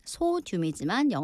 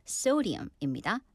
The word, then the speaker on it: sodium: So sodium, 소듐이지만 영어 발음은 sodium입니다.